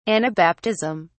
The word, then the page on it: anabaptism: British English